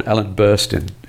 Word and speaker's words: Ellen Burstyn: In terms of famous names in this one, Ellen Burstyn is reprising, is that the correct pronunciation? – Yep.